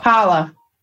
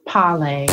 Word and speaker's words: Parler: Parler. – Parler. Or they say it's pronounced parlay.